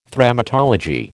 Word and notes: Thremmatology: American English

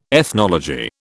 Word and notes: ethnology: British English

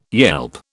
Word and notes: yelp: British English

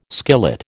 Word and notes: skillet: American English

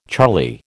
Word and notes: charlie: American English

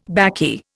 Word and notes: baccy: Indian

